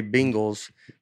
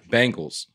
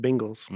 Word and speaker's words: Bengals: I couldn't help but notice that when we have another penguin situation on our hands, the way Nate and Breadcrumbs say Bengals sounds like it's spelled Bengals, B-I-N-G-L-E-S.